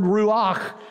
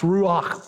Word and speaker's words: ruach: The Hebrew word ruach, meaning both breath and spirit. Well, heck, you can't even pronounce ruach properly without breathing out. It's the sound of a breath.